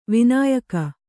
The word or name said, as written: ವೈನಾಯಕ